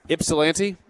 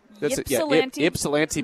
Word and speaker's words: Ypsilanti: Ypsilanti? – Ypsilanti, Michigan.